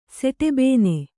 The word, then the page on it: ಸೆಟೆಬೇನೆ: kannada; seṭe bēne